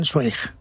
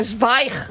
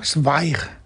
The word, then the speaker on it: Zweig: Tú sabes que yo toda mi vida he estado diciendo que yo leía Stephen Zweig. – Ah, claro. Es no. Zweig. – Zweig. – Zweig.